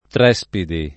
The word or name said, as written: Trespidi